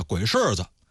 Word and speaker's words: 鬼市子: 鬼市呢在古代后边多一个子叫鬼市子，也可以念成轻声叫鬼市子。